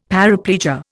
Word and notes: paraplegia: Indian

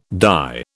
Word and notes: dye: British English

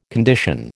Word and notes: condition: American English

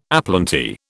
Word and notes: aplenty: British English

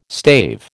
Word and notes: stave: American English

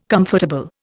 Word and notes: comfortable: Indian

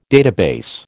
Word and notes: database: American English

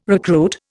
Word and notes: recruit: Indian

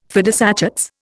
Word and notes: fidus Achates: Indian

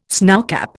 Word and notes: snowcap: Indian

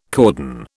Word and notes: cordon: British English

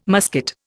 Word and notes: musket: Indian

